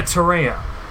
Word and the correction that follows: Tara: Tara. Tia Tara. One syllable.